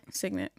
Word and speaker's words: Signet: Like, it's gone to waste with her, like, Singit abilities. And is that what you say? Signet. Signet abilities.